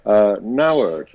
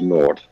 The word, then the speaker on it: Nauert: Nauert is apparently the correct pronunciation. I thought it was Nauert, as in nothing, a big fat zero.